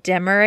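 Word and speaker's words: D-MER: And I mean, some of that you can work through and there's solutions for, but prolonged pain or even things like DEMR, Demmer, I think is how you pronounce it, where dysphoric milk ejection response, where you, when your milk lets down, have a very emotionally disorienting response to that.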